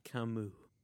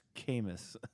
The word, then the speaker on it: Camus: I was reading the works of Albert Camus. – It's pronounced Camus.